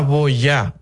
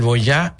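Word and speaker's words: Boyah: Y se llama Boya,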